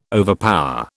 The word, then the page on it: overpower: British English